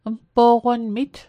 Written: mpo'qonmit

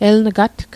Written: eln'gatg

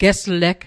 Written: gesleg

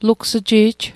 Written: lugs'ji'j

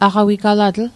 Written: aqawigalatl

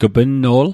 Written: g'pnno'l

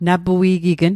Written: napui'gign

